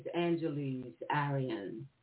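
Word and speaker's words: Angeles Arrien: her name is Angeles Arian. You pronounce her name, you know, you spell it A-N-G-E-L-E-S.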